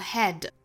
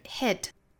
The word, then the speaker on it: hit: 大家不要读成了 hide 或者是 head 这个词大家在读的时候可能会读的口型过大，就是发成了 head，要注意是 hit，